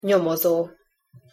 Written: nyomozo

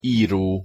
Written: iro

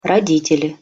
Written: roditeli

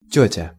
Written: tetja

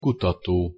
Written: kutato

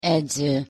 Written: edzo

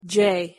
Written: j

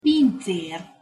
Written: pincer

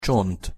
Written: csont

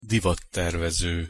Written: divattervezo

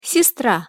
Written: sestra

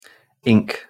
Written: ink

